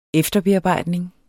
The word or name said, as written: efterbearbejdning